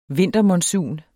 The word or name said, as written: vintermonsun